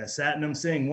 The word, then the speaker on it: Satnam Singh: Satnam Singh? – Yeah, Satnam Singh, one in a billion.